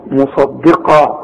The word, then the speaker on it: مصدقا: القاف بقى المفخمة مصدقة، خد بالك في عندك صاد عاملة زي الجبل وقاف عاملة زي الجبل وبينهم دار رؤيئة، فالدال دي هتطلع منك ضاد اذا ما خدتش بالك من نطقها. لازم تقول مصدي ان الدين عند الله الاسلام. شوف الدال بقى ان الدين اي مصدي مصدقا مصدقا لما معهم.